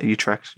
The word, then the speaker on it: Utrecht: Yeah, Utrecht.